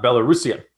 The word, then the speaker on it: Belarusian: Before we started taping, I would have bet a significant amount of money that it was pronounced Belarusian and not Belarusian. No, I was all ready to make fun of Greg. I was all ready. And then I looked it up, and lo and behold, Belarusian is the correct pronunciation.